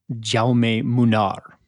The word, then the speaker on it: Jaume Munar: It's pronounced basically Jaume Munar. Like you kind of pronounce all the vowels in Spanish.